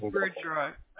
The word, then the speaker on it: Bordeaux: I apologize, it's Bordeaux. I said it incorrectly.